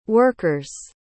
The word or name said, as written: workers